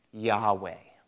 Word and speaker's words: Yahweh: This beautiful personal name of God, the best we know how to pronounce it is probably pronounced something like Yahweh.